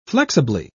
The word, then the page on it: flexibly: British English